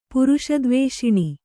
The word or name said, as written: ಪುರುಷದ್ವೇಷಿಣಿ